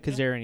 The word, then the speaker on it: Kazarian: And that was a really good match because, like you said earlier, Kazarian is a, am I saying that right? – Kazarian, yeah. Yep.